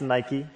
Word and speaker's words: Nike: It's not Nike. It's Nike.